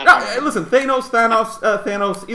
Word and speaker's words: Thanos: Um, how do you pronounce the bad guy from Infinity Wars, Jared? – It's Thanos.